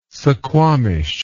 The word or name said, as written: suquamish